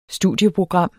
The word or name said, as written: studieprogram